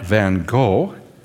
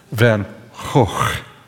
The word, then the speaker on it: Van Gogh: Anyway, this is Starry Night, painted by a great Dutch painter, post-impressionist painter, 1898, and his name is Vincent, not Van Gogh, that's English, not American English, not Van Gogh, that's British English. The G is pronounced in the Netherlands as a rough H, just like hate. Hoch, Van Hoch.